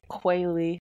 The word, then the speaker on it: Quayle: but not spelled like the bird, but said like it. Looks like Quayle. – Q-U-A-Y-L-E.